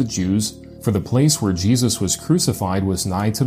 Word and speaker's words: Iesus: What they're leaving out is that the letter I was pronounced the way that we pronounce the letter J today. So if it's written I-E-S-U-S before 1524, it was still pronounced Jesus.